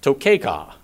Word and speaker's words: tokeha: But it's interesting, this Hebrew word, tokeha, is how it's pronounced in English, T-O-K-E-H-A.